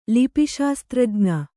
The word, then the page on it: ಲಿಪಿಶಾಸ್ತ್ರಜ್ಞ: lipi śastrajña